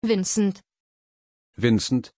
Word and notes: vincent: German

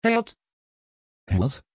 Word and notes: herrad: Dutch

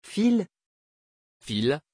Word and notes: phil: French

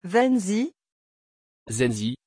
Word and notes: Zenzi: French

